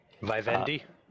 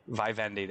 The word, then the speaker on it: Vivendi: Vivendi. – Vivendi.